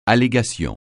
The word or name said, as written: allégation